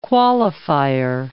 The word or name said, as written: qualifier